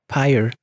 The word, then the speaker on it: Pyre: Ah, ça se prononce comme ça? Oui. – Pyre. – Pyre. Pyre.